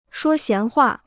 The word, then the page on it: 说闲话: shuō xián huà